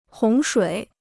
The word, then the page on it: 洪水: hóng shuǐ